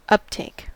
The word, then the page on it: uptake: American English